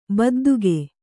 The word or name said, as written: ಬಿದ್ದಿಕ್ಕು